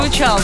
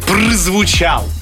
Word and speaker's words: прозвучал: Прозвучал, да. – Очень правильно, Арфаэпия. Прозвучал. – Арфаэпия? Простите, пожалуйста. – Московская норма произношения.